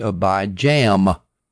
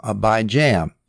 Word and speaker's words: Abijam: And Rehoboam had a son, and he was named Abijam, J-A-M. I'm going to pronounce it weird, just to emphasize the jam, Abijam.